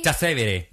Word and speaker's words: Trastevere: Trastevere. – Yeah. – Trastevere.